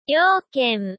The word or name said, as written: youken